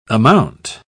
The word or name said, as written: amount